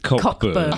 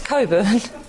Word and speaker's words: Coburn: Yeah, it's spelt that way. It's pronounced Coburn. – Okay, spelt Cockburn, pronounced Coburn. Okay. Yeah. Interesting. So the C and the K are silent.